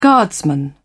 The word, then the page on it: guardsman: British English